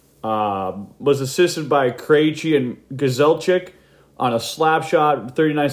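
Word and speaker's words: Grzelcyk: And he was assisted by Smith and Matt Gazelchik. Yes, I pronounce it the Polish way, not the American Canadian way, which is butchered all the time.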